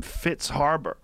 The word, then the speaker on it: Fritz Haber: There's a guy named Fritz Haber.